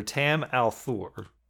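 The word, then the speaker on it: Tam al'Thor: Rand's father, Tam Althor, I assume I'm pronouncing that correctly.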